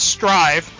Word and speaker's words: Strive: And the latest game, and I gotta make sure I say this right, is Strive, not Stride.